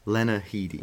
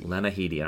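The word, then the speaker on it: Lena Headey: What is it? Headley? Yes, Lena Headley. – Lena Headley.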